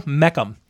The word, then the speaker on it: Mecham: Shortly after, they were contacted by the company's owner, Michael Meckham. That's Michael Meckham, ladies and gentlemen. Michael Meckham. Let me spell that for you. M-E-C-H-A-M.